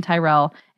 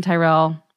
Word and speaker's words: Tyrrel: All my social media handles are just under my name, Kirsten Tyrell. It's spelled weird. It's T-Y-R-R-E-L. Social media, Kirsten Tyrell.